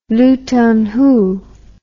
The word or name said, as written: Luton Hoo